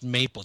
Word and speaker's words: MPLE: MPLE, pronounced Maple.